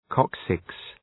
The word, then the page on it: coccyx: 'kɒksıks